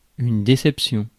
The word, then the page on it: déception: France; /de.sɛp.sjɔ̃/; French